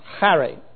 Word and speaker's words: Charis: Charis in the Greek, though they pronounce it without the hard sound, Harry. So I like it because it's my name, C-H-A-R-I-S, but they say it Harry.